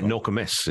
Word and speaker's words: Nokomis: It's a Nokomis brown ale from, sorry, Nokomis is the way it's pronounced.